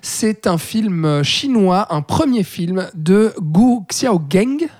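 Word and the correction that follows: Gu Xiaogang: Je pense que je prononce absolument très mal. On dit Xiao. pardon, oui, oui, Gu Xiao Gang.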